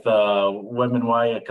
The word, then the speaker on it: Wembanyama: The problem for the Iowa Senate Democrats is there's no legislative draft lottery with Wembanyama coming in. I know I butchered that. How do you pronounce that name? – Wembanyama. – Wembanyama. Thank you.